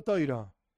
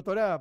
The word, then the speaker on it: Torá: Yo soy Ashkenazi. Y por lo tanto digo Toiro. A veces digo Torá para que sea más sencillo, porque sale así.